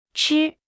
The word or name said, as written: 吃